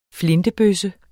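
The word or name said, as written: flintebøsse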